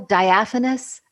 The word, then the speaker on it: diaphanous: This love of words, how did I do on pronunciation? – Oh, perfect. Perfect. Ephemeral, diaphanous, and squat, and obstreperous.